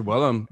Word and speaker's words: Willem: I used to say William like Goblin. So much, like, and then somebody corrected me. I was like, whoops, I guess I've been saying his name wrong for like years. – Yeah, and it's Willem as in Will E-M.